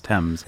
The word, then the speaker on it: Thames: Thames.